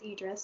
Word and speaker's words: Idris: No, that is how I always called him Idris. Apparently, it's Idris, so I'm sorry.